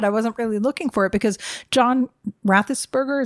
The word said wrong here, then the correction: Ratzenberger: is that how you say his name? – Ratzenberger.